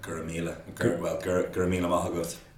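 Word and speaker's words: go raibh maith agat: How do you say thank you again? – Guramila. Guramila Mahagut.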